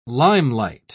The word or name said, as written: limelight